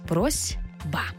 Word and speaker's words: просьба: Ми точно знаємо, що це слово пишеться з літерою «с» – «просьба». Але як ми його вимовляємо? «Просьба». Чуємо звук «з». Чому так відбувається? Ще раз закріплюємо правило. Глухий звук «с» стоїть поруч із дзвінким звуком «б».